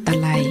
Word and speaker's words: ตะไล: คำว่าตะไลสะกดด้วยตอเต่าสระอะไม้มาลายลอลิงอ่านว่าตะไล